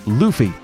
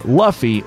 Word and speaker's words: Luffy: In the promo, they teach new fans that the main character's name is pronounced Luffy, not Luffy or Luff-I.